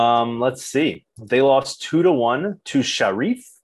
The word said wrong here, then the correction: Sheriff: Sheriff. It's spelled like sheriff.